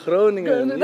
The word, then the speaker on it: Groningen: So you're born in, how do you say it, Groningen? – Groningen, yes.